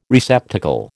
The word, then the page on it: receptacle: American English